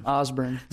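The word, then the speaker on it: Osborn: Osborne. Yeah. – Some people pronounce it Osborne. – Oh, yeah. – But that's O-S-B-O-R-N, right? – Right, yeah.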